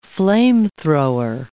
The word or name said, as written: flamethrower